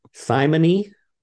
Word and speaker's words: simony: Simony, is that how you pronounce it? – Mm-hmm.